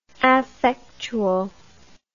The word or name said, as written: affectual